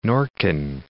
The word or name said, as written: Nordkyn